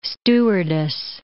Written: stewardess

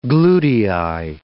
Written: glutei

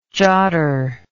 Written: jotter

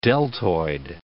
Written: deltoid

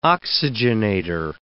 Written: oxygenator

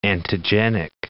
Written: antigenic

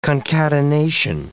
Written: concatenation

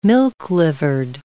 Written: milk-livered

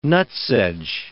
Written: nutsedge

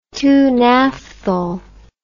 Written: two name paper

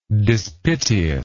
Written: dispiteousness

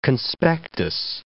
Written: conspectus

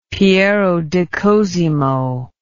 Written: Piero di Cosimo

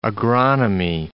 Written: agronomy

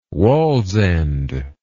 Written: Wallsend

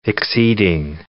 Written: exceeding

